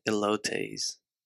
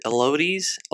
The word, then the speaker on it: elotes: Oh, is it elotes? Elotes? Why am I asking? I know how to say it. Elotes. I heard so many people just pronounce it terribly, like, I'll have an elote.